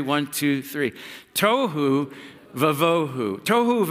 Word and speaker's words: tohu vavohu: It's this word, it's pronounced tohu vavohu. Sounds like tofu, that's not tofu.